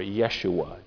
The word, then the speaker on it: Yeshua: The word, the name Jesus is the Greek form of the Jewish name or the Hebrew name Joshua or Yeshua, if you want to pronounce it Hebrew and impress your friends.